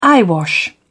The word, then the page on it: eyewash: British English